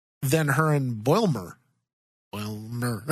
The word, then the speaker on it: Boimler: If without her, if you pair Mariner with Bulimer, I hope I said that right. – Boimler. Think Boimler. – Of Boing.